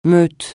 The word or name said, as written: meute